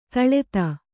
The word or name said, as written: ತಳೆತ